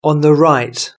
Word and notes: on the right: British English

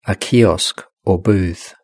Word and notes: a kiosk or booth: British English

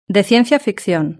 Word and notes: de ciencia ficcion: Spain

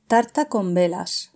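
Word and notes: tarta con velas: Spanish (Spain)

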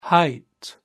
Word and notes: height: British English

